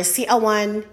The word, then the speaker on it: siaw: It is spelled as S-I-A-W and pronounced in two syllables as siaw, siaw.